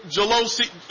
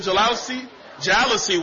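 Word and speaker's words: jalousie: My wife and I, when we first got married, we had a 1,200-square-foot apartment with those old jalousies? That's how you pronounce it, the jalousy windows.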